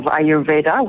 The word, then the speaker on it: Ayurveda: and you are correct with your pronunciation of Ayurveda.